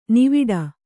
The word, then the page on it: ನಾವಾಟ: nāvāṭa